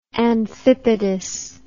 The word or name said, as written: amphipodous